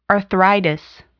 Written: arthritis

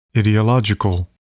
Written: ideological

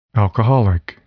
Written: alcoholic

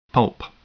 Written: pulp

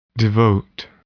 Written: devote

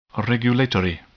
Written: regulatory

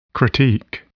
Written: critique

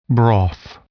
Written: broth

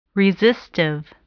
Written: resistive

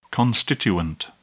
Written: constituent

